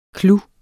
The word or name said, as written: clouer